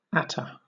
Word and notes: attar: British English

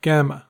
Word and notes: gamma: American English

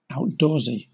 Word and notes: outdoorsy: British English

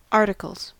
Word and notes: articles: American English

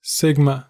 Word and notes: sigma: American English

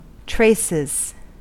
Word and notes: traces: American English

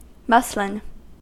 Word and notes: muslin: American English

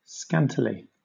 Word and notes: scantily: British English

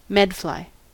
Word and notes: medfly: American English

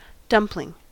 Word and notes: dumpling: American English

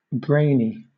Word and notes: brainy: British English

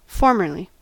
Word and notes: formerly: American English

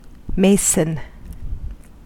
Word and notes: mason: American English

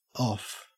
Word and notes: off: Australian English

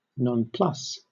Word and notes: nonplus: British English

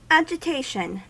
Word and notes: agitation: American English

